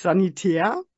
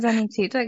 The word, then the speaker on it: Sanitäter: Nicht aus dem Sanitäterbereich, ja, diesmal vielleicht nicht ganz so verkehrt ausgesprochen, nicht Sanitär. – Sondern Sanitäter, genau.